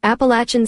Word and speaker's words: Appalachian: Here's the official pronunciation. – Appalachian State. – Appalachian State. Not Apple.